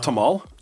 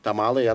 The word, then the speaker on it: tamal: It's pronounced tamal? – Tamale. – Yeah. I thought it was tamale. – No, tamale, I don't know what that from is. Tamal, that's the way you pronounce it, tamal.